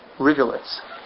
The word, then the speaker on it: rivulets: Because Ye'or actually means little rivulets. Am I pronouncing that word correctly? Mm-hmm. Rivulets. Okay. So that's what Ye'or means.